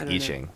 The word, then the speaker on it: I Ching: Or, I don't, is that even how you say it? – I think it's I Ching. – I Ching? – I mean, whatever. That's I call it I Ching. – You call it I Ching? – Yeah. – I'm just as white as can be. – I'm going to be real. Like, my pronunciation of it comes from a monologue in You've Got Mail. That's what Tom Hanks says it, and so I'm going with it. I'm calling it I Ching for the rest of the fucking rest of this podcast.